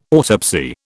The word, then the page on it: autopsy: British English